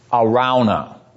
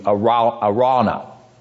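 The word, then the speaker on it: Araunah: It's pronounced Arauna, like it was R-A-W instead of R-A-U, Arauna.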